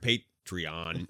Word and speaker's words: Patreon: That's the word, Patreon. – It's a weird pronunciation, but it's correct. – It is technically correct.